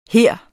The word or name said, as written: her